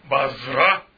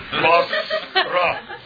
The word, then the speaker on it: البصرة: وليست بازرة كما يقول يسمونها في التلفزيون الأمريكي، يعني هم يحتلون البلد ولا يعرفون كيف ينطقون اسمه. أليس هذا مضحكا؟ يعني أنا أتحدث إليكم، I'm reporting to you from بصرة، واسمها بصرة بالصاد، بصرة، حاكم البصرة لا يعرف كيف ينطق اسم مدينته.